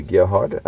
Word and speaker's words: Gerhard: Yeah, it's actually Gerhard. A lot of people call me Gerhard. But the actual correct pronunciation is Gerhard.